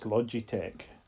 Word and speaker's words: Logitech: It's pronounced Logitech.